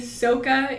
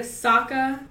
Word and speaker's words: Sokka: So saying Soka instead of Sokka, Aang instead of Aang, Opa instead of Appa.